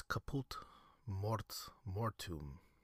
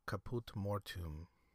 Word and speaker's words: Caput Mortuum: Next is Caput Mortuum. Caput Mortuum. What do you think that color looks like? It's spelled C-A-P-U-T space M-O-R-T-U-U-M.